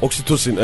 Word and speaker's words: Oksitosin: Bu oksitokin diye bir şey var ya. – Oksitosin evet. – Oksitosin mi? Hı. Ha doğru, Almanca olunca S okunuyor. Oksitosin.